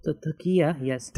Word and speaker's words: Taqiyya: I mispronounced it, Takiya? Taqwa? No. – Taqiyya, yes. – Taqiyya, there you go.